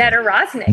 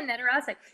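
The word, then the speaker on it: Nedoroscik: Steven Nodorowski. I cannot pronounce his name properly. – Nedorosnik. Yeah, he's got a hard one. Yeah, Nedorosnik.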